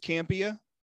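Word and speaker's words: Campea: I pronounced it wrong for the longest time when I was first introduced to him. – But it is Campia.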